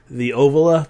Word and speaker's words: Ovila: It was the Ovila, but you call it Ovilia. Start adding vowels to it for some reason.